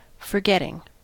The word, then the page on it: forgetting: American English